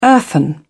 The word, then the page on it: earthen: British English